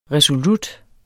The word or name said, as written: resolut